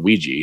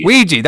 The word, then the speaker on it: Ouija: How do you say that? Ouija? Anji board. – I say it Ouija. – Ouija. That's what I was looking for.